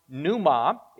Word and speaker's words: pneuma: The word for Spirit here is the Greek word pneuma. It is spelled with a P, as you can see on the PowerPoint slide here. The reason is the study of the Holy Spirit is called pneumatology. This particular Greek word pneuma is sometimes translated as wind.